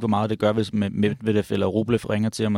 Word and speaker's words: Medvedev: fordi selvfølgelig er det et hårdt skæbne for Medvedev, jeg kan ikke engang udtale hans navn. – Medvedev, ja.